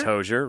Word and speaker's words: Hozier: And is that how you say it? Hozier? – I think it's Hozier, right? – Yeah.